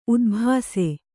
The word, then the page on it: ಉದ್ಭಾಸೆ: udbhāse